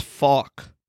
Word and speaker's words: phoque: S-E-A-L, like the animal. – Urban Dictionary says that it's fawk. – Oh. – What's fawk? – That's close enough. – P-H-O-Q-U-E. Okay, anyway, now that we know that it's fawk, what would you do with this?